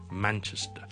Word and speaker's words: Manchester: 另外一个大城市呢亦都是一个我们很多人都读得麻麻地的一个音呢，就是曼彻斯特，我们是读 Manchester。 The stress is on the first syllable. Okay, man, man, man. 后面只是一个很轻的 Chester 或者 Chester 来联动。